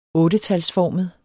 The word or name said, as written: ottetalsformet